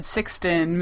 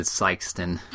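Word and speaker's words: Sikeston: No, they said Sixton, Missouri. – Well, they did say it properly when I crossed. They said Sykeston.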